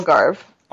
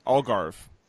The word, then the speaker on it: Algarve: Yeah, and on Rebecca Quinn, she was also named to Canada's roster for the Algarve Cup. Am I pronouncing that right? – Algarve. – Algarve. – Yep. – Gotcha. No, no vey at the end there.